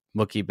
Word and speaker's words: Mookie: I would like to just take a moment to pay homage to the fact that you say Mr. Betts' first name as if it rhymes with Wookiee, like from Star Wars. Mookie Betts.